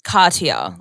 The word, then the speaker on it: Cartia: My name is I hate introducing myself because do I say Cardia? You actually pronounce my name Cartier. Like there's a T in there, but we're very bogan Aussie and I just kind of skim over it and say Cartier Milan.